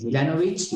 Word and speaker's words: Milanovic: Sí, Milanovic, la C final se pronuncia Che.